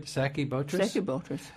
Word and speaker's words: Stachybotrys: Stachybotrys.